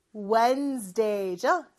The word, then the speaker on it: Wednesday: 수요일은 Wednesday가 아니고 Wednesday죠. 그래서 D 발음은 생략된다 라고 보시면 돼요.